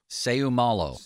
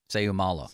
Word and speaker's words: Seumalo: Seumalo. – Say who? Seumalo. – Oh, Seumalo.